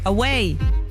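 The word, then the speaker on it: aweille: If you've ever spent time in Quebec, you've probably heard someone say, away. That's A-W-E-I-L-L-E.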